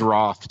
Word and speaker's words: wrath: Yeah, and he says Roth, too, not Wrath.